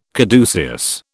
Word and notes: caduceus: British English

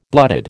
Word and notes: blooded: American English

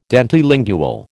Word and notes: dentilingual: American English

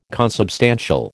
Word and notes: consubstantial: American English

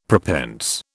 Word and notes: prepense: British English

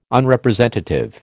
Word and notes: Unrepresentative: American English

